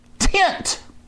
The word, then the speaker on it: Tynt: Geek Software of the Week this week is Tint. T-Y-N-T, Tint. So you've got to spell it funny so you can get a URL. Just say it. So T-Y-N-T, Tint.